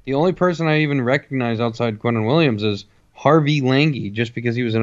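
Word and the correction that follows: Langi: It's pronounced Longy.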